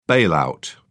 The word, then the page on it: bailout: British English